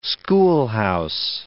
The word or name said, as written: schoolhouse